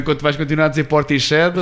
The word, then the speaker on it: Portishead: Acho que é importante falar também, porque passei anos, e penso que vocês também, a chamar a banda Portishead, até descobrir que não é a pronúncia correta, deveria ser sempre assim, Portishead.